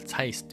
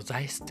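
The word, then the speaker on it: Zeiss: 脱線しましたが、まあ、その 2 人がねやっぱりなんつうんだろう、えー、そういう、えー、ドイツのね、えー、ブランドとかを日本人はみんなサイスって言いますよね。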